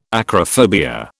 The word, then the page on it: acrophobia: British English